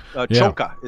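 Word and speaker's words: Csonka: Or you could imagine yourself being Larry Zonka, talking about a Miami Dolphins guy. – And a Bo Hunk, right? He's a Hungo, isn't he? – He's a Hungo. Chonka is how you pronounce that.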